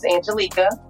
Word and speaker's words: Angelika: Pronunciation of my name's Angelika. – Ooh, and I'm always messing up somebody's name. – It's okay. It's spelled like that. I told my mom she should have put a C in it.